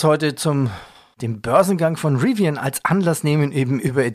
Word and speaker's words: Rivian: Wer es verpasst hat, Rivian, Rivian wer oder Rivian, wie wird es richtig ausgesprochen? Rivian. Rivian, ja, das ist jetzt plötzlich der zweitwertvollste Autobauer der USA.